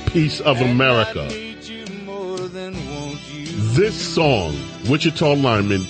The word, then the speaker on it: Washita: There's another way I could pronounce this, but I'm not going to. It's spelled W-A-S-H-I-T-A. Wichita.